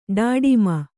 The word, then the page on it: ಡಾಡಿಮ: ḍāḍima